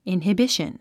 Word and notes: inhibition: ìnhəbíʃən; インヒビション

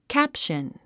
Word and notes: caption: kǽpʃən; キャプション

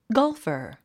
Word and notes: golfer: ɡάlfə r; ゴルファー

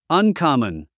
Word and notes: uncommon: ʌnkɑ́mən; アンカモン

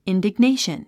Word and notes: indignation: ìndiɡnéiʃən; インディグネェション

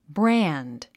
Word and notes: brand: brǽnd; ブラァンド

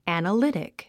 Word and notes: analytic: æ`nəlítik; アナリティック

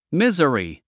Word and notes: misery: míz ə ri; ミザリィ

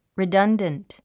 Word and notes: redundant: ridʌ́ndənt; リダンダント

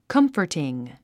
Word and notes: comforting: kʌ́mfərtiŋ; カンフォティン

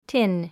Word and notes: tin: tín; ティン

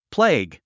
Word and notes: plague: pléiɡ; プレェイグ

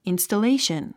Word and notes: installation: ìnstəléiʃən; インストレィション

